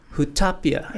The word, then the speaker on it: Hutapea: Hutapea.